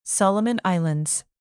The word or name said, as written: solomon islands